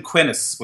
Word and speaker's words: Quinnis: We called him Quinnis, which rhymes with Guinness.